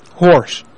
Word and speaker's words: Horse: Horse. – Is Is that H-O-R? Can you spell it for me, please? – H-O-R-S-E.